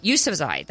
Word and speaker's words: Yousafzai: The youngest laureate is Malalu, I think I'm saying this right, Yousafzai. That's right, Yousafzai. That's how you say it.